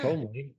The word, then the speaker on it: comely: It's C-O-M-E-L-Y. – Yeah. – Fuck. – And it's pronounced, it says, sounds like K-U-H-M-L-E-E.